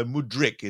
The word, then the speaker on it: Mudryk: Just to clear up exactly how you say it, first of all, it's Mykhailo Mudryk, isn't it? – Yeah, as far as I'm aware, I've heard a fair bit of Mudryk, but I've been asking people and kind of trying to dig in.